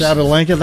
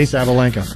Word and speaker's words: Sabalenka: Well, I'm going to butcher this name. Arnya Sabalenka? Did I come close? – Sabalenka? That sounds about right to me, yeah. – Okay. – Sabalenka.